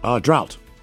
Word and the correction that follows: draught: D-R-A-U-G-H-T. – Yeah. Why is that hard to say? – Drought? Actually, no, it's draft. – Draft. So there's a silent F. – D-R-A-U-G-H-T. Draft.